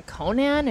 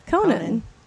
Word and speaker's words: Conan: You've seen her on Conan and At Midnight. Dulcé Sloan. – Hello. – Hi. Hello. – You said Conan. – How do you say it? – Conan. You said it like Conan the Barbarian.